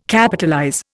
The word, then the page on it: Capitalize: Indian